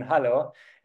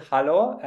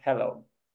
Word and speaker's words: hallo: So that is the first and probably most important word to learn in German, hallo. It's very similar to the English word hello, but be sure to pronounce the A differently, like Germans would, hallo and not hello.